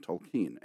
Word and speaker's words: Tolkien: Early on, Lewis's closest friendship was with Tolkien, and I pronounce it that way because I believe it's the way he pronounced it.